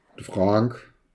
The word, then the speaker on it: Fraenk: F-R-A-E-N-K, ist richtig ausgesprochen, Frank,